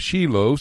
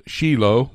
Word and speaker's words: Shiloh: Shiloh. We in the United States sometimes mispronounce it Shiloh, but it's Shiloh in Israel.